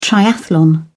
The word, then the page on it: triathlon: British English